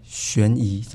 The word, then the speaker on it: 悬疑: 悬疑怎么念？ – 悬疑，你已经念出来了。